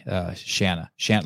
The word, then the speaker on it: Shana: Shana, like rhymes with banana.